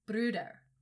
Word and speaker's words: Bruder: And it's Bruder.